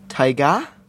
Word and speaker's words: Tyga: I think it's pronounced Tyga. I think with a little, like, it's French.